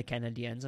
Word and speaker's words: Canadiens: Do you like that I keep on calling them the Canadiens and not the Canadians? – I do appreciate the pronunciation.